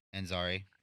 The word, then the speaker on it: Ansari: I can never say his name right. Is it Ansari? – Ansari.